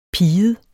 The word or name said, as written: piget